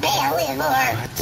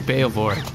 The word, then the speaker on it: Baelivor: As ordered by Kor Baelivor. – Wow. Kor Bael. It's actually Baelivor. – Baelivor. There's an E there. – No, yeah, but it's not. It's you don't pronounce the E.